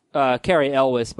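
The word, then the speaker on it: Cary Elwes: Carey Elwes, by the way, is how you pronounce it.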